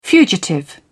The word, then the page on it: fugitive: British English